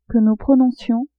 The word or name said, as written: que nous prononcions